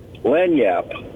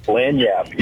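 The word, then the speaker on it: lagniappe: Lanyap. – Lanyap? – Lanyap.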